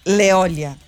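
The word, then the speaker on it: Léolia: Fui ver o nome mesmo, Leolia Janjan mesmo, porque o que escreve-se Janjan. – Janjan. – É. Isso. – Leolia Janjan. – É Leolia. – Leolia Janjan, exatamente. Ou seja, a pronúncia está Leolia correta.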